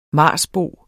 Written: marsboer

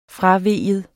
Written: fravige

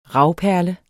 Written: ravperle

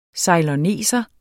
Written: ceyloneser

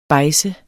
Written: bejdse

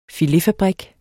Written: filetfabrik

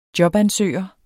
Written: jobansøger

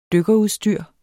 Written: dykkerudstyr

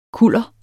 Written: kuller

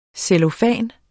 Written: cellofan

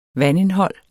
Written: vandindhold

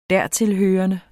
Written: dertilhørende

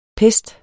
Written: pest

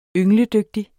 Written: yngledygtig

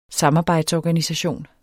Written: samarbejdsorganisation

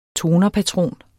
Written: tonerpatron

